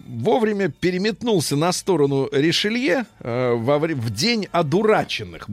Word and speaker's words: Ришелье: День одураченных э, — это 1630 год, э, когда влиятельные враги, враги кардинала Ришелье, надо правильно фамилию с Ё говорить,